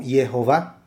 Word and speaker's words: Jehová: Él es un experto en rollos antiguos y por lo que él ha concluido en sus estudios, el nombre se pronuncia como Jehová.